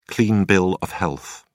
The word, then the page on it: clean bill of health: British English